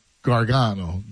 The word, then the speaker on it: Gargano: It's supposed to be, like, the old world says it, like, Gargano. Gargano is how you say it.